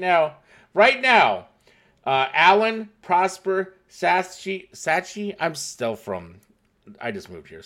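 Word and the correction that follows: Sachse: Let me help you out. I've been in the market a little while. Saxy, thank you so much. I've been pronouncing that wrong this entire time.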